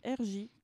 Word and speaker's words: RJ: La galerie RJ, comme un R et un J.